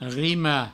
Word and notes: rimar: rima; Provençal

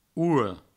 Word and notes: ora: ouro

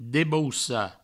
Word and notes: debaucar: Provençal; debaussa

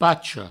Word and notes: pacha: pacho

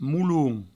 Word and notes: molon: mouloun

